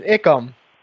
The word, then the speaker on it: Icum: They walked right by me, and they laughed at one of the building names at Pennhurst, which is Icum, but it's spelled I-C-U-M.